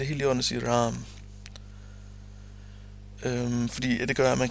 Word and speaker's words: Rama: Det skal endvidere siges, at i mantraet, der staves ordet Ram, det staves Rama. Rama. Men det udtales Ram med et stumt dag til sidst og med et langt af i midten.